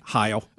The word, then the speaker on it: Heil: Is it how you say his name? – Heil. – Let's go back to Bob Heil. – It's H-E-I-L. Heil.